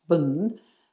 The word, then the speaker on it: Bon: And then in Tibet, before Buddhism came, there was an indigenous religion which was called Bon, which I think is pronounced Bun,